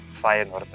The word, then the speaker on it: Feyenoord: Ako se negde pojavila varijanta Uh, bez tog E u sredini reči u pitanju je disleks, kako mi volimo da kažemo, to je se najnormalnije izgovara, dakle ili Feyenoord ili Feyenoord i ništa i ništa treće.